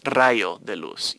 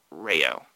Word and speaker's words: Rayo de Luz: It's Rayo de Luz. You said Rayo, which is fine. It's, you know, a non-Spanish speaker's pronunciation of the word.